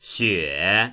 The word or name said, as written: xue3